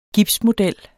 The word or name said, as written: gipsmodel